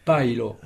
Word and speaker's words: pajlo: Alors, paello, c'est de la paille. Très bien, Pierre. Ça s'écrit P-A-J-L-O. Il n'y a donc que deux syllabes. Paille et puis l'eau. Donc, il faut dire paello.